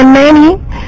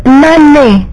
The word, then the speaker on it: nane: And then for number 8, I think you say nani, it's nane.